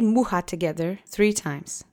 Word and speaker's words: mucha: The accent is on the first syllable. Mucha mucha. Mucha. Great. And I hope you remember the CH letter combination sounds like a H. So it's not CH, it's H.